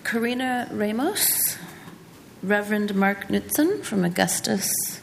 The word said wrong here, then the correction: Augustana: I didn't say that right. Augustana. – Augustana.